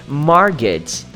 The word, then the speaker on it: mortgage: Bom, mas mortgage, mortgage. Teacher, mas cadê a pronúncia do T? Não tem. Você não precisa falar mortgage, é mortgage.